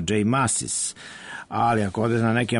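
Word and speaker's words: Mascis: onda kažu da on svoje prezime izgovara zaista kao Maskis, mada se to po načinu na koje je to napisano ne bi nikad rekao.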